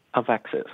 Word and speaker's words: AveXis: AVEXIS. – AVEXIS. And you pronounce it how? – AVEXIS.